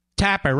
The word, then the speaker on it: Tapper: Tapper rhymes with clapper.